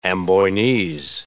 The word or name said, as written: Amboinese